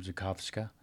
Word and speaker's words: Zakowska: Zakowska.